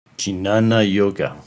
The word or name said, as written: Jnana-Yoga